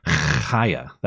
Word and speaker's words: Chaya: It's Chaya. That's how you pronounce it.